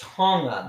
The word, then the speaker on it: Tonga: It's actually just pronounced Tonga. There's no G, but yes.